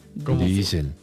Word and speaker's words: Diesel: Y en alemán se pronuncia diesel como con una I larga. – Diesel.